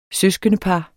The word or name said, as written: søskendepar